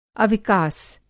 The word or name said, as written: अविकास